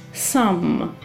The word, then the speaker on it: thumb: Здесь есть «би» на конце, но она не читается. Большой палец – «сам».